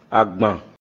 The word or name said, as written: Àgbàn